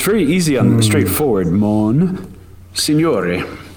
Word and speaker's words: Monsignore: Monsignore. Or Monsignor, as you might. – Oh, Monsignor. Okay. – Yeah, in English, Italian it's Monsignore. In French it's Monseigneur.